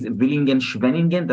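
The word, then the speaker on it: Villingen-Schwenningen: And now the family is at the the, the right name is Villingen Schwenningen. That's the right right way to pronounce it yes, it's like at the eastern border of the Black Forest.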